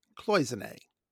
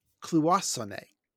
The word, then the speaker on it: cloisonné: Filippo Di Paola issued a correction on Facebook about our pronunciation of the word. Well, we pronounced it cloisonné. Filippo says cloisonné. Well, you might be right, but it would not surprise me if it is pronounced that way in French or Italian. But every English dictionary I could find, they pronounce it the way we did. And they might be giants pronounced it that way.